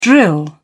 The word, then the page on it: drill: British English